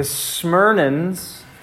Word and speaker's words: Smyrnans: The Smyrnans, that's how you say it, they had to ask that.